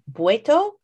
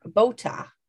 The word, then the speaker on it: Botha: there's a couple different ways to pronunciate her last name, which is Botha or Botha. And it's spelled B-O-T-H-A, if anybody wants to go look that up.